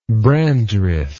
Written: Brandreth

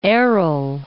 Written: Aral Sea

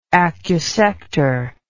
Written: acusector

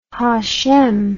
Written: hashem

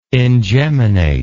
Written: ingeminate

